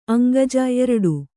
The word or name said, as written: ಅಂಗೀಜು